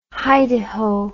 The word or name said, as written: hi-de-ho